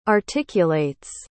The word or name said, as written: articulates